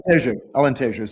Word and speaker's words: Alentejo: Talking about up-and-coming, and you mentioned it earlier, there's one of the other regions, a part of Dão, has been Antelheiro. Sorry, my pronunciation. – Oh, Alentejo, sorry.